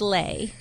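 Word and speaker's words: Leigh: Well, she goes, it's like Slay. So she says Lay.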